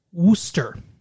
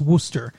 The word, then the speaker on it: Worcester: And in Massachusetts, you pronounce that city Worcester. – Okay. – Even though it's spelled like Worcester, it's pronounced Worcester. So that sauce is Worcestershire sauce.